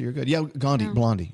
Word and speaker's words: Blondie: And by the way, I realize Blondie rhymes with Gandhi in case anyone texts in. – Oh, Blondie.